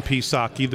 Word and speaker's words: Psaki: We have, on this show, focused a lot on Jen Psaki, the P is silent, and her press briefings.